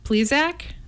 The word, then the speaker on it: Plesac: Um, the M- MLB Zach Plesac. Is that the right way to say his name? – Sure.